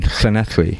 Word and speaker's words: Llanelli: Llanelli. Sorry, my pronunciation. – No, it was good.